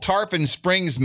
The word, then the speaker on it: Tarpon Springs: 64-year-old Tarpon Springs man wearing a Speedo was arrested for flashing his 8-inch penis to a woman on the beach.